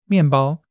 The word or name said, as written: mian4 bao1